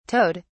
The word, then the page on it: towed: British English